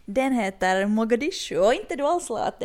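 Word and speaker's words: Muqdisho: Mogadishu, precis, uttalas på somaliska Mogadishu. jag ber om ursäkt för det uttalet, men kallas också av somalierna för hamar.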